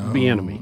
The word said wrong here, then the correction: Bimini: Oh, Bimini. – Is that how you say it? Bimini? – It's like bikini, from what I could find here.